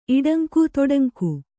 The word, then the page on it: ಇಟ್ಟಂಗಿ: kannada